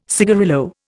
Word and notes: cigarillo: Indian

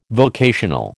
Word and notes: vocational: American English

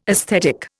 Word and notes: esthetic: Indian

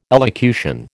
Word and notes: elocution: American English

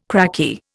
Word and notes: cracky: Indian

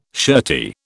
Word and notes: shirty: British English

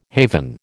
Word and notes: haven: American English

